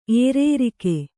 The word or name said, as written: ಏರೇರಿಕೆ